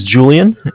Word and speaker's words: Julian: My name is Julian. It's spelled J-U-L-I-A-N.